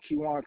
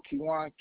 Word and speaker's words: Keiwan: My name is Kiwan Kirkland. – Could you spell that for me? – Oh yeah. My first name is Kiwan, K-E-I-W-A-N. My last name Kirkland, K-I-R-K-L-E-N-D.